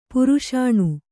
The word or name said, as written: ಪರಸನೆ